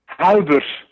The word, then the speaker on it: Huibers: Halbers. – I can't pronounce it. H-U-I-B-E-R-S.